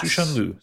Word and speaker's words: Cixin Liu: but I believe it's Cixin Liu. – Yes. – Cixin Liu, something like that. Spelled Cixin.